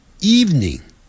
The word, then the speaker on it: evening: 只讲两个音节 evening eve evening evening evening evening evening 这样，但不要念成 evening，是 evening，那个 v 的音很短 evening evening good evening，那是晚上好 good evening。